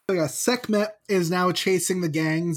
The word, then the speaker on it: Sekhmet: And I'm probably going to butcher her name, Sekhmet. – Sekhmet, yeah. – I'm going to say, yeah, Sekhmet.